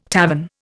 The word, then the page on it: tavern: Indian